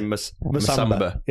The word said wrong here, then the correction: Musaba: Yeah, it's Musaba.